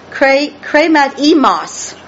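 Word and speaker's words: chrematismos: And it's kremat emos, with emphasis on the last syllable, M-O-S. Kremat emos.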